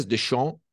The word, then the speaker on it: Deschamps: Deschamps, uhum.